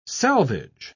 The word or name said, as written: salvage